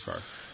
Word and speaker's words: Favre: It's probably pronounced like Favre. – It's pronounced Favre. – Why? – I don't know, because he likes that better than Favre.